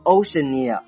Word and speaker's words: Oceania: 接下来我们来看大洋洲，它呢读作 Oceania， Oceania 拼成 O-C-E-A-N-I-A，这个单词啊非常好记，里面有一个海洋的意思，所以呢它就是名词大洋洲。这里面我强调一下发音，不要顺着 ocean 读下来，读成 Oceania，它读作 Oceania。